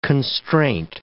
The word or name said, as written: constraint